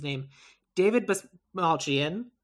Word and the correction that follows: Dasmalchian: David Dasmalchian. – Dasmalchian.